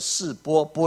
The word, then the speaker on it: 示非: 好，示非这个字哈，其实发音叫做示波，玻璃的波，示波的意思。